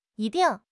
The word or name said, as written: yi1 ding4